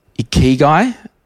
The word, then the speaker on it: ikigai: Ikigai, the Japanese secret to long and happy life.